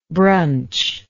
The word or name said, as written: bruncher